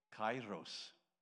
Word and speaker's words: kairos: Ah, word, kairos. Pronounced K-A-I-R-O-S, spelt K-A-Y-E-R-R-O-S, kairos.